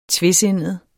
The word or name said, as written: TVESINDET